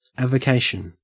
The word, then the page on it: avocation: Australian English